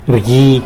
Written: rgui

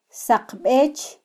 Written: saqbech

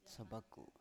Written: sabaku

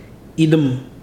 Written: idịmidim

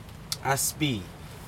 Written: aspii

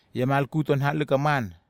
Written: iamalkut to nalhekam

